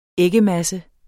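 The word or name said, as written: æggemasse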